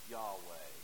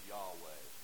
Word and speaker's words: Yahweh: We're not sure we're pronouncing it correctly. But for the most part, historically, we think it would have been pronounced as Yahweh.